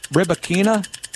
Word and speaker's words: Rybakina: Rybakina? – Yes, that's right.